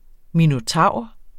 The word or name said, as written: minotaur